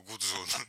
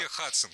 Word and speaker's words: Hudson: Значит так, если миссис, то миссис Hudson, а если залив, то Гудзон. Ну, вообще Hudson, конечно.